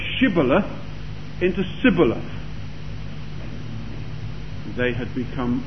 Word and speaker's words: Shibboleth: And if he said, "Nay," then they said unto him, "Say, Shibboleth." And he said, "Sibboleth," for he could not frame to pronounce it right.